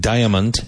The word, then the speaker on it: diamond: Now, even though it's spelled diamond, it's diamond. Diamond.